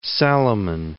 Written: Salomon

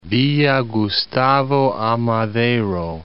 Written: Gustavo A. Madero, Villa

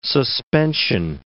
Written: suspension

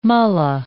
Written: mullah

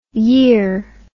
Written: yere

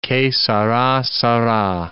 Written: che sara, sara

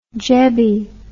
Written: jebby

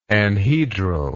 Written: anhedral

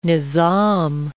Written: nizam

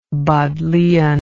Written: Bodleian

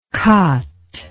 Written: kat

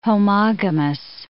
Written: homogamous

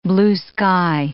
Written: blue-sky

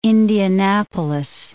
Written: Indianapolis

